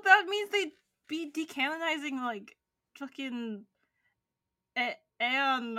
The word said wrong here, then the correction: Ain: Ain, sorry. – You have to say it like you sound like you're in pain.